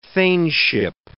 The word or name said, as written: thaneship